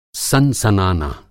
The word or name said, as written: सन-सनाना